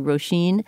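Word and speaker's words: Róisín: Okay, well, she was one musician that we talked about whose name we apparently mispronounced because it's one of those, you know, Irish names that's spelled quite differently from how it's pronounced. It's actually Roisin.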